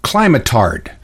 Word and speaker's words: climatard: We're going to learn a new word here, and I'll phonetically spell it for you. It's KLY-MIT-TARD. It says climatard.